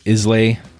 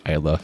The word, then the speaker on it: Islay: It looks like it says Islay, but it's actually pronounced Islay.